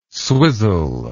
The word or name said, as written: swizzle